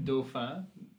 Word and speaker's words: Dauphin: Dauphin.